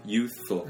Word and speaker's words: youthful: 한국 사람들이 th 좀 힘들어 하시죠. 예, youthful. s는 아닙니다. 예. s 들어가면 안 돼. 그러니까 th 그렇게 표기하는 거 제가 반대하는 이유는 사람들이 잘못된 발음에 익숙해질 수밖에 없어요. 근데 사실 useful 하고 그거 아예 다른 단어입니다. – 그러니까 예, useful 유용한 그리고 youthful s 안 나요. s 소리 안 납니다.